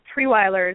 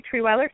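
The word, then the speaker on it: Trierweiler: Because my maiden name is Castiglione, so it was hard, but Treweiler is tricky. So if you have pen, I'll spell it for you. It's T-R-I-E-R-W-E-I-L-E-R. So it's Jody Treweiler Fitness.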